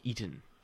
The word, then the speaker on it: Eaton: And then the name of his character in Raiders of the Lost Ark is General Eaton. – Eaton. – It's spelled E-A-T-O-N, but it sounds like eating.